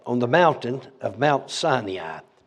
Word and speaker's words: Sinai: Brother Hagin said Sinai. So in honor of Brother Hagin, I say Sinai. Remember how you say it? Sinai? Remember he'd say Sinai. So I just do that in honor of him.